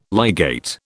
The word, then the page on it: ligate: British English